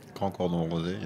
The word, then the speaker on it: Grand Cordon Rosé: For our weekly wine sip this week, a Mum Cordon Rouge Rosé. Did I say it right? – Grand Cordon Rosé, yeah.